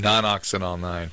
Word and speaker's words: nonoxynol-9: Nonoxynol-9.